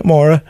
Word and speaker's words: Maura: Have I pronounced your name right? Is it Maura? – Yes, it's an Irish name, so you're bang on.